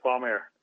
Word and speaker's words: Palm Air: Yes, Palm Air.